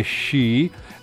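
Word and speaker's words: Shi (四): Il numero 4 porta sfortuna perché la pronuncia cinese Shi è simile a quella della parola morte.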